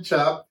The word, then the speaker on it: Chupp: Ken Chubb from Indiana, Warsaw, Indiana.